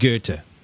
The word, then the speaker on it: Goethe: Or the, I would have said it's Goethe. I would have thought it was Goethe. But then I went to college, and they said Goethe.